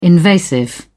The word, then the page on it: invasive: British English